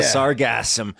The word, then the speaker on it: sargassum: Sargassum. – Awesome. – Heavy emphasis on the gas, guys. S-A-R-G-A-S-S-U-M.